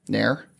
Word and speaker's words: Knehr: What would you go with that? K-N-E-H-R. Nair? – I think you nailed it. Nailed I'm not it. Even going to try. – Okay, we'll go with Nair.